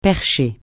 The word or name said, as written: percher